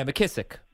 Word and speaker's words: McKissick: So I'm trying to debate between Sonny Michel and J.D., McKissick, that's the way you pronounce his name? – Yeah, McKissick.